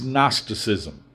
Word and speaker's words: Gnosticism: Now, in a word, the error at Colossae was Gnosticism. The G is silent. It comes from the word gnosis in the Greek language, which means knowledge.